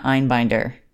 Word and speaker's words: Einbinder: It's Jean Smart and Hannah Einbinder. I believe that's how you say her name.